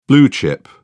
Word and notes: blue chip: British English

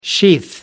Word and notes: sheath: American English

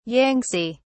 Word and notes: yangtze: British English

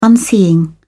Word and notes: unseeing: British English

